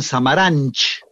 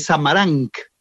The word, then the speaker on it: Samaranch: ejemplo, un directivo eh, eh, deportivo también catalán se llamaba, lo pronunciaban Samaranch. Y, es Samarank todos los, apellidos catalanes que terminan con CH se pronuncia C final.